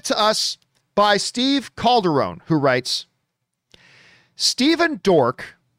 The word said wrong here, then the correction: Dorff: oh, I'm sorry, I mispronounced that, Dorf.